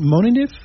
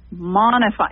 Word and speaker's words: Monifi: So tell us a little bit about, how do you pronounce it, Monif? – Monify. I know. It's Monify. Okay. – Monify. – M-O-N-I-F-I. Okay. Let me tell the listeners. – Yes. – So M-O-N-I-F-I.